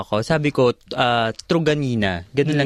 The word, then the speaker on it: Truganina: sa kanila, sa, sa, sa pagsasakay ko doon sa mga, pag may uh, private service, ganyan, Truganina.